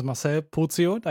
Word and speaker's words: Marcel Posio: My name is Marcel Posio. I think that's at least the German pronunciation.